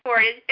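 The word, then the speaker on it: FORDD: Do you pronounce it Ford or Ford D-D-D? – It's Ford. It's short for Forensic Drug Diversion Clinic.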